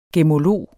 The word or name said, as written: gemmolog